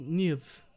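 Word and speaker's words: Neefe: Aparte de su gran crecimiento, fue gracias a su profesor Christian Nife. Espero lo haya pronunciado bien, fíjate. No, es Nif. Nif.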